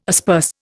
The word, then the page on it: asperse: Indian